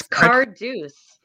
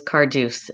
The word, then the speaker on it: Cardews: People think I've spelt my name wrong all the time and assume it's Cardoza, but it is Cardews.